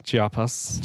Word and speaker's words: Chiapas: Chiapas.